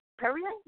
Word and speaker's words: Perrier: Perrier? Not Perrier. – Oh, Perrier? – Perrier. This is sparkling water, right? And it comes from France, right? – Perrier?